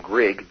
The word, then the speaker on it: Grigg: If you Google Pro Libertate and my last name, Grigg, G-R-I-G-G, that will certainly be the first result that pops up.